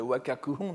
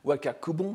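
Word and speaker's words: Wakakuhon (和歌九品): En particulier, il y en a un qui s'appelle le Wakakuhon, n'est-ce pas, qu'on prononce aussi Wakakubon,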